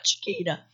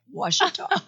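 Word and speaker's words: Ouachita: It's not Chiquita. – Chiquita. – They had to teach me. My friends are like, no, it's like think of a washateria, like Washita.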